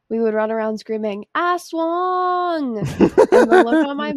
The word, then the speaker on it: aswang: I live in Georgia, but my mom is from the Philippines, and I grew up hearing about and watching movies about aswangs. – Sorry. Aswangs? – And then in parentheses, okay, it says, pronounced like aswang.